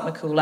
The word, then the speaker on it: Mac Cumhaill: It's not spelt McCool. It's spelt M-A-C-C-U-M-H-A-I-L-L. But that is spelt McCool, actually. – M-H is like a W. – Yeah. Mm. – In Irish, yeah.